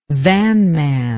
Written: vanman

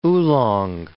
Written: oolong

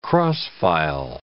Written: cross-file

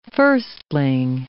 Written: firstling

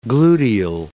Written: gluteal

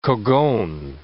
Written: cogon

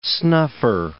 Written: snuffer

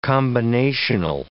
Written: combinational

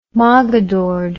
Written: moggadored

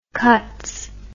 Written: cuts

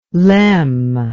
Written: lem

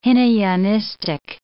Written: Hinayanistic

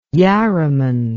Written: yarraman

